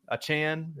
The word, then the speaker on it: Achane: Am I saying that wrong? Achane.